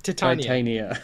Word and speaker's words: Titania: No. Titania, you said. Titania. Like the queen of the fairies.